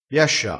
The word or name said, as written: biascia